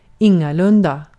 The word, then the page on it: none: /nʌn/